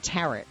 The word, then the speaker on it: Terrett: If you want to add to the comment, Terrett, I keep saying it wrong. And so I keep saying Tourette, like Tourette syndrome, right? John Terrett, my apologies, John.